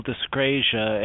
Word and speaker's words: dyscrasia: Okay, I can't say that word. Dyscriasis? No, I said that wrong. – It was meant to be plasma cell dyscrasia, and what it means, dyscrasia is just a fancy word to say when a plasma cell goes wrong, okay.